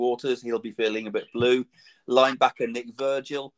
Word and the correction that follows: Vigil: Um, I think that's a bit Vigil, not Virgil.